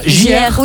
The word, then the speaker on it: JR: Enfin, nouveau projet pour l'artiste, ça se prononce comme ça, JR.